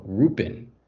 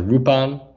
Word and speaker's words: Lupin: you hear the translation being either Wolf or not Lupin, but Rupin. Rupan, actually. R-U-P-A-N. Rupan, which is the Japanese pronunciation of the word.